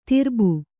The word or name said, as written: ತರುಬು